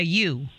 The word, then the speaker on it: ewe: The female sheep is called a ewe. – Yeah. I would have never got that. – Me neither. And it's spelled, if we ask you how it's spelled, that'd be even crazier. It's E-W-E. – That's crazy. – A ewe. – What? I would have been like, you.